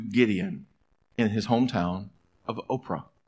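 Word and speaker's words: Ophrah: Now the angel of the Lord came and sat underneath the terebinth at Oprah, which is how this word is pronounced.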